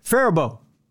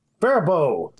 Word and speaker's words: Faribault: Yeah, well, there's a story that came out in 1999 in, I'm going to completely butcher this name, Faribault. – Faribault. – Faribault.